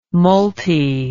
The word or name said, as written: multi